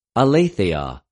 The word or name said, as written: ἀληθεια